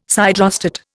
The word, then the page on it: siderostat: Indian